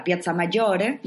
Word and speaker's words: Piazza Maggiore: Entonces, nada, yo recomiendo empezar por la Piazza Maggiore, ¿no? La quiero pronunciar así con ese acento italiano. – Te ha quedado muy bien.